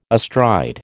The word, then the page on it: astride: American English